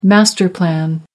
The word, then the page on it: master plan: American English